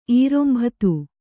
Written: ಈರೊಂಬತು

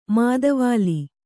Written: ಮಾದವಾಳ

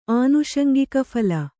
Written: ಆನುಷಂಗಿಕಫಲ

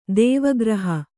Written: ದೇವಗೃಹ

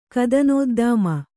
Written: ಕದನೋದ್ದಾಮ

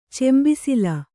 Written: ಚೆಂಬಿಸಿಲು

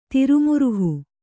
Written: ತಿರುಮುರುಹು

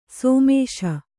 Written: ಸಮಸು

